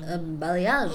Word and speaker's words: balayage: It's literally like how it's spelled is exactly how you would say it. – Yeah. – But the G and the E make like a Z. – A balayage.